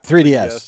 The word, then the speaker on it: 3DS: Yeah, because I know my other daughter had it on her DS3. Is that what am I saying it right? – Yeah, 3DS.